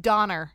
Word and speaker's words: Donner: It's pronounced Donner. – Donner party.